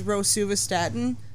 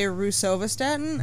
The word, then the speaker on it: rosuvastatin: The hard one for me is rosuvastatin. My brain wants to say rusovastatin, and I don't know why.